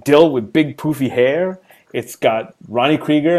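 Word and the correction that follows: Creager: and LeVar, Krager, because we've all been saying it wrong for 20 plus years, 25 years.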